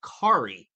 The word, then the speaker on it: Kari: Yeah, and I was actually talking to Kari. Now he's like, I went up to him like, hey, what's going on, Kahari? He's like, hey, just want to let you know the H is silent.